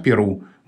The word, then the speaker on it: Перу: получил распространение вариант «Перу», близкий к языку-источнику, и он закрепился в русском языке.